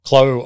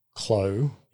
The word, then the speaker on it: Clos: So, Clos Orange Chardonnay from 2022. – And this is C-L-O. – Correct. – Yeah. – Yep. Yep. So, it's like the pronunciation of Clos in French, which is spelt C-L-O-S. – Oh, okay. – C-L-O in Australian.